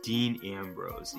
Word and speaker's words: Dean Ambrose: We are looking at the match that pitted Bray Wyatt against Dean Ambrose.